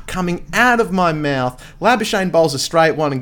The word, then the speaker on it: Labuschagne: It's about 10 or so minutes before lunch, and Minus Labuschagne comes on the bowl, and my sister's like, who's this Labu Cagney fella? And I'm like, look, Labuschagne, I've only learned how to say it this year myself, sis, so I know where your head's at there.